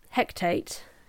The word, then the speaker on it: Hecate: Sorceress, who is a student, pretty much, of Hecate. Is that how you say it? Yeah. We'll just say that's how it's said.